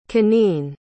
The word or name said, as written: Kanene